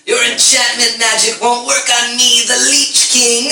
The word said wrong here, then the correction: Lich King: I shout out, did you say Lich King? – did you actually say that?